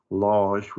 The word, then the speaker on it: Lage: It's like a Lage with a soft G.